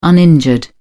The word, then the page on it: uninjured: British English